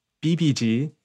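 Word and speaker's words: VIVIZ: On Wednesday, another debut, very, very exciting one, is the new girl group BBs, or maybe it's closer, more accurately pronounced BBG, with their mini album Beam of Prism and the title track Bop Bop.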